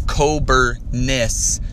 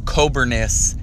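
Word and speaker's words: Kobernusz: Coberness. The U is an I without the Z. Coberness is how you say my last name.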